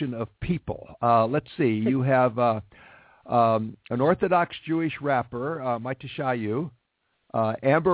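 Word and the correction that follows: Matisyahu: Riley, Mitishahu, sorry, you heard Mitishahu.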